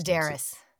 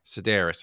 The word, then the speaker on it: Sedaris: Is it how do you say her last name? – Sedaris. – Sedaris, I thought.